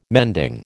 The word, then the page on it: mending: American English